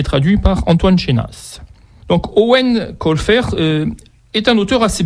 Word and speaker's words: Eoin: Owen, oui, c'est comme ça que ça se prononce, mais ça s'écrit E-O-I-N.